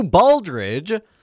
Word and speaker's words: Baldridge: Coming up next here, folks, we're going to have Drew Bolt. Okay, I guess I can't say his name. It is Drew Baldridge coming in here with more music.